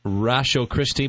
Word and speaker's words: Ratiochristi: That's pronounced Rasho Christie, but it's spelled R-A-T-I-O-C-H-R-I-S-T-I. Rasho Christie.